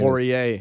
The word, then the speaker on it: Poirier: Poirier or whatever it is. – Poirier. – Whatever. – I helped Bones with the pronunciation.